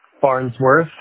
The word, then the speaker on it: Farnsworth: Farnsworth.